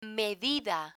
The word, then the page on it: medida: spanish